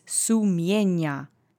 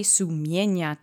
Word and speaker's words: sumienia: And the last word is sumienia. It means conscience. It's the singular object form of the noun. Let's say sumienia together three times. Accent is on the middle syllable. Sumienia.